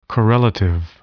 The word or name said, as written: correlative